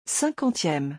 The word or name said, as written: 50eme